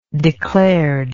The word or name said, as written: declaredly